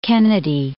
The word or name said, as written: Kennedy